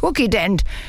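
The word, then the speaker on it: Kukident: Zuzident, Drei-Phasen-Reiniger. – Oh, Frieda, das schreibt man mit K, das heißt Cookie-Dent.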